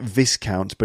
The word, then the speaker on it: Viscount: Then we have Marquess, which is actually spelled M-A-R-Q-U-E-S-S, but it's pronounced Marquess. Then you have Earl, E-A-R-L, Earl. And after that, you have Viscount, which is actually spelt V-I-S-C-O-U-N-T. It looks like Viscount, but it's actually Viscount.